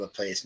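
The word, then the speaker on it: LaPlace: All of our cities, like La Place, that's why we call it La Place. Everybody comes in here, they want to call it La Place. No, it's La Place, Baton Rouge, which is almost all the immediate cities around the city itself were pronounced in French.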